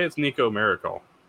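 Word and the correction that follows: Marchiol: Nico Markiel.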